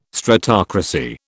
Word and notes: stratocracy: British English

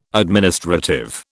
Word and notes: administrative: British English

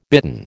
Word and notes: bitten: American English

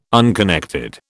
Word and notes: unconnected: British English

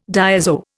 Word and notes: diazo: Indian

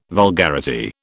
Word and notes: vulgarity: British English

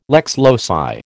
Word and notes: lex loci: American English

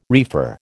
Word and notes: reefer: American English

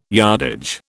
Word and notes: yardage: British English

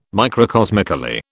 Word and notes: microcosmically: British English

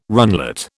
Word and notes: runlet: British English

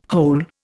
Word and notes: hole: Indian

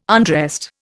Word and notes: undressed: Indian

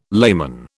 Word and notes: layman: British English

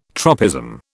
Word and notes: tropism: British English

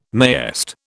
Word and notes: mayest: British English

